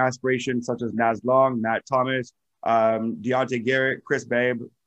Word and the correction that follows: Babb: I don't know if I said his name correctly. – Chris Babb.